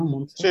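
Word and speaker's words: Mundstock: Nos estamos refiriendo a el miembro fundador de Lelutier, del grupo argentino Lelutier, Marcos Moonstock, creo que así se pronuncia así, ¿no? Moonstock.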